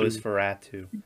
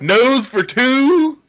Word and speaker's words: Nosferatu: It's not nose, it's nos. Nosferatu. – No, Nosferatu. – You keep saying it. Nose for two? – Yeah.